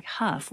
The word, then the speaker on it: Hough: We're going to continue this hour with a piece from Sir Stephen Huff. I'm just going to make a little disclaimer here. His name is spelled H-O-U-G-H, and he calls himself Huff, as do the dancers on Dancing with the Stars.